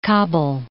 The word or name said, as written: Kabul